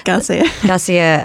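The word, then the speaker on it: García: When I think of magical realism, I think of, Gabriel Marcia, I can't even say his name. – Garcia, 100 Years of Solitude.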